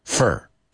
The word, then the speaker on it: fur: Now, of course, you can always pronounce it for, but the other ones, no. The other ones are fur all the time. F-U-R, pelo, pelaje, and F-I-R, which is abeto. And fur, abeto, although it looks like it, does not rhyme with fear, miedo.